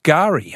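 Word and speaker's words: K'gari: Fraser Island is Kigari pronounced Gari. Okay, you don't pronounce the K, says Mad Dog.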